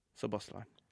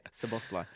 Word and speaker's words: Szoboszlai: And Szoboszlai, who I keep saying his name wrong. Szoboszlai. – Yeah. – Szoboszlai. I'll be putting a bit of swag on it. That's wrong. Okay. Szoboszlai.